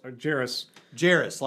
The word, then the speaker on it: Jarrus: And if you want a response to our friend Kurt Jarrus, who's also Jarrus like Paris.